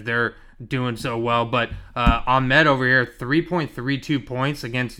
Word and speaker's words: Ahmed: Ahmed, man. Ahmed. – Ahmed. – Come on, Ahmed. – We grew up in Silicon Valley. How do you not know Ahmed? – Oh, don't.